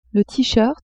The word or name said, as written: tee-shirt